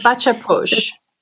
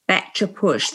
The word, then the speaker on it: Bacha Posh: Baka Posh, is it? Baka Posh? – A Bacha Posh. – Bacha Posh.